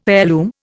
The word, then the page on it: heirloom: Indian